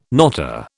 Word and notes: knotter: British English

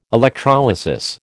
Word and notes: electrolysis: American English

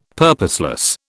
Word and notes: purposeless: British English

